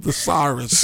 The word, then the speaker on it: thesaurus: Thesaurus, walking Thesaurus. Did I say that right? – Yes, you did.